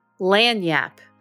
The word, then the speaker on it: lagniappe: lagniappe. Did I get that right? – You so got it right. Lagniappe, it's a little bit extra. So today, think about what you can do with your lagniappe.